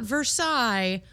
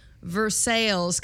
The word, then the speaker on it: Versailles: Um, yeah, we've been uh, staying at an Airbnb outside of Lexington in the little town of not Versailles, Versailles, Kentucky. – It's it's spelled like Versailles, but it's pronounced American.